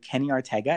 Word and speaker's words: Kenny Ortega: So Kenny Ortega, I said that right, Devon? – Yes.